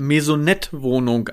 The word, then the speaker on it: Maisonettwohnung: Nicht falsch verstehen, aber meine Frau sagt, wenn die Kinder ausziehen, dann ziehen wir in eine Marionettenwohnung und jetten durch die Welt. – In eine Marionettenwohnung, eine Maisonettwohnung, André. Ja, ja, Maisonettwohnung. Okay, ich habe das falsch ausgesprochen.